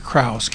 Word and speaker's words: Krause: My name is Kurt Krause, K-R-A-U-S-E.